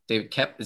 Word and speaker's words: David Koepp: David Koepp.